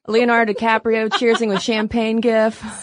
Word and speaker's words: gif: It's a really effective gif in a lot of situations. And yes, I do say it with a hard G.